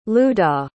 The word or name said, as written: luda